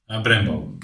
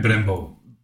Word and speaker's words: Brembo: Brembo. – Brembo. – Nee, Brembo.